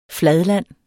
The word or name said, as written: fladland